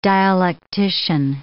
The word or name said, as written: dialectician